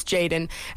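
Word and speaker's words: JXDN: I'm not sure how you pronounce this. J-X-D-N. I think it's Jaden.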